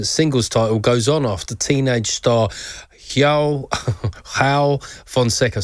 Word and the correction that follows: Fonseca: sorry, I've butchered that, Fonseca produced a stunning fight back to win a 5-set epic in the French Open third round.